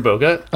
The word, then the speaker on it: Bogut: Am I saying it correctly now? – It's like getting closer. – Say it again. Can you say it? – Andrew Bogut? – Oh, no, that's good.